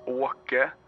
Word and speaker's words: Åke: I'm looking for a guy or somebody who knew this guy, and this is a guy whose name is Oki. I don't know. – Do you mean Oke? – Oke. Is that how you'd pronounce it? – Yeah.